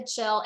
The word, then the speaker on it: Kjell: So, and what's really neat is, so Kjell, my new husband, spelled K-J-E-L-L, it's Norwegian, but he grew up in Idaho, pronounced Kjell.